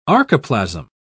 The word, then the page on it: archoplasm: British English